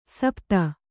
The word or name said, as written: ಸುಪಥ